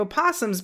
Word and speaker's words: opossum: In North America, we have opossums, but it's okay if you don't pronounce the first O.